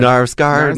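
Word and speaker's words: Knausgaard: How do you say Carl Nufsgaard? How do you say Carl Narsgaard? – Narsgaard.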